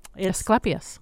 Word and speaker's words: Asclepias: Asclepias.